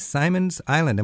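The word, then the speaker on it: Simons Island: St. Simon's Island.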